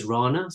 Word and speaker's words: Rana: and obviously my surname is Rana, so it rhymes with banana, so I'd say Rana Banana.